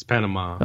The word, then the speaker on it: Panama: It's there's an A at the end of Panama. It's Panama. – Oh, what it's did not, I say? I said you, you, Panama. You, you, always say you say you say it like enema. – You say it like Pan- enema.